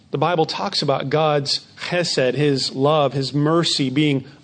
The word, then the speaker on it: chesed: In the Old Testament, we often read of a particular term that in Hebrew is pronounced chesed, which is sometimes hard to translate into English, but it carries the idea of love, compassion, mercy, faithfulness.